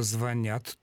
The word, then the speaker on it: звонят: Сергей Константинович, конечно же, звонят только так. В этом глаголе ударение на корень не падает никогда. Звонить, звонят, звонишь и так далее. Только на окончание.